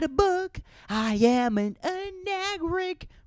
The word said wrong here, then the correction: arachnid: How do you say that? Arachnid, anagric. That sounds like a very, like, Arabian black racial slur.